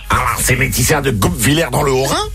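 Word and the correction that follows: Guebwiller: On y est presque, la prononciation est pas ouf. – Vous avez raison, madame Patton. Je sais pas parler. Comment qu'on prononce? – C'est Gab Villers.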